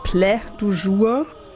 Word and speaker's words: Plat du Jour: Das war jetzt musikalisch auch nicht mein Favorit heute, aber ich dachte an sein Konzeptalbum, es schreibt sich Plat du Jour. Anscheinend spricht man es Plat du Jour.